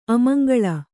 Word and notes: ಅಮಂಗಳ: amaŋgaḷa

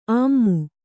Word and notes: ಅಮ್ಮು: ammu

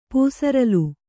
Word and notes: ಪೂಸರಲು: pūsaralu

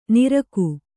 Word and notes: ನಿರಕು: niraku; Kannada

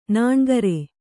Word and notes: ನಾನ್ಕಾರ್: nāŋkār

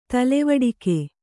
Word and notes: ತಲೆವಡಿಕೆ: kannada

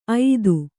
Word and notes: ಐದು: aidu

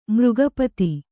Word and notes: ಮೃಗಪತಿ: kannada; mřga pati

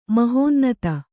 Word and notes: ಮಹೋನ್ನತಿ: mahōnnati

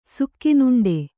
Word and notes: ಸುಖಿನುಂಡೆ: sukhinuṇḍe

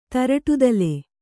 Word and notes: ತರಟುತಲೆ: taraṭu tale

